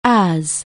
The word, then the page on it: as it were: anglais